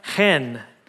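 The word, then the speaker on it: chen (חן): It's pronounced chen. Everybody say chen. Yeah, it's all the way back there. Really get your throat into it, chen.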